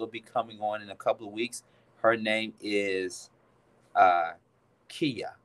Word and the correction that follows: Kaya: Did I say it right? Kaya or Kia? It's Kia. I think it's Kia or Kaya. No. I'm sorry. My mistake. Kaya. – Right. Kaya. Yeah, Kaya.